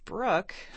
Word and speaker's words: Bruch: Let's listen to Max Bruch. It's actually spelled B-R-U-C-H. It's not like brook as in stream. That's how you say it, though.